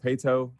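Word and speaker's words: Paetow: Well, Coach, you're a Katy kid, born and raised, and now you're there coaching at Katy Pato. Am I saying that correctly? Is it Pato? – Yes, sir. Pato.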